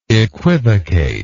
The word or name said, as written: equivocatory